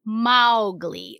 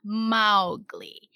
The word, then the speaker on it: Mowgli: According to the author Rudyard Kipling, it's pronounced Mowgli, like Mao like the cow, Mowgli.